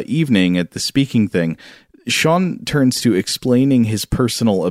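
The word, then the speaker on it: Sjón: It is about a speaking event with an Icelandic novelist who goes by the name Sean, spelled S-J-O-N, but pronounced Sean.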